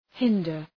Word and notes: hinder: ‘haındər

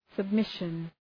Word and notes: submission: səb’mısʃən

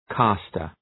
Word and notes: caster: ‘kæstər

